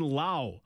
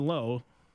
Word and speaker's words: Lowe: Brandon Lowe. Don't call him Brandon Lowe. Brandon Lowe, L-O-W-E, just like Josh Lowe. Same spelling, same team, different pronunciation.